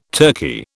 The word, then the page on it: turkey: British English